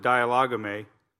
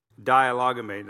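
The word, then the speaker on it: dialegomai: but I pronounce it dialogame. Dialogame.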